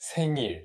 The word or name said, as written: birthday party